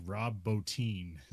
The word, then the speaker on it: Bottin: Yeah, it looks like Rob Bottin, but it's like Rob Bottin is how you say his name.